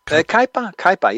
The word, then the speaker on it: Kaipa: Kaipa, yeah.